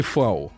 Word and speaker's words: Фау: Вот, это читается по-немецки Фау. Она вообще звук F, то есть вот фатер слово, оно пишется через V, как бы, да.